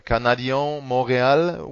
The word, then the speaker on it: Canadiens Montréal: Uh, he would say the uh, Canadiens Montréal would be his pronunciation,